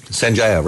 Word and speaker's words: Sanjaya: And this year it's that Sanjay. Am I saying that right? – Sanjaya, right. – Sanjaya, okay.